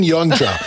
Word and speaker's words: Yonca: Y-O-N-C-A. Das ist wichtig. Ben kann man sich gut merken. Aber Yonja mit Y und dann ein C. Also wenn es Ben Yonja, aber nur mit C-A ausgesprochen. Oder Zusatz geschrieben, aber ausgesprochen anders.